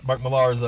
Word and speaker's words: Millar: Netflix bought uh, is in partnership with with uh, Mark Miller's Mark Millar's.